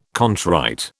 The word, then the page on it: contrite: British English